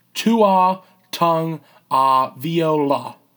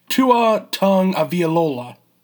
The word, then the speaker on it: Tagovailoa: So the big story coming out of the college football championship game, bear with me on this pronunciation, Tua Tagovailoa. Let's try that one more time. Tua Tagovailoa. No one really knows how to say it that well. One more time. Tua Tagovailoa.